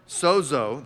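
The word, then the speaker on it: sozo (σῴζω): The verb sozo, that's how you would pronounce that, and that's what it looks like in the Greek.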